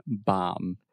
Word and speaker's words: bomb: The B is silent. Bomb. – Aye, that's true. Okay, bomb. – You're still saying a B at the end.